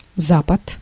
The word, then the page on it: ouest: France; [wɛst]